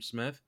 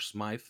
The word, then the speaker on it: Smythe: They have Durham Smith, or Smythe, like some people say his name, and Adam Shaheen.